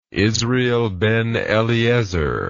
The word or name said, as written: israel ben eliezer